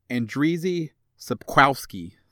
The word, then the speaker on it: Andrzej Sapkowski: The author, the author of the Polish book series, his name is Andrzej Sapkowski. I nailed that, so don't worry about the pronunciation. That's how you say it.